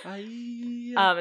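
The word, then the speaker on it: Aeaea: I think it's pronounced Aia. There's a lot of vowels. – Aia. – It's like all vowels. – Aia. – But like Aida. – Yes.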